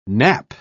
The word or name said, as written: knap